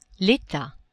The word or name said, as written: etat